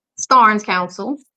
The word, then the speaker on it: Starnes-Council: Starnes-Council.